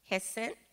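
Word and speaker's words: Chesed (חֶסֶד): What is the word? – Chesed. – Chesed? – Chesed. – Chesed. – That's how you say it.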